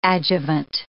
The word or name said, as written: adjuvant